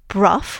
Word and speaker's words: Brough: Yes, yeah, so Lady Anne Clifford is connected with two of English Heritage's most spectacular ruined castles, Brough, which is spelt B-R-O-U-G-H, and Broom, which is spelt B-R-O-U-G-H-A-M, and they're both in Cumbria, and they're both very much well worth a visit.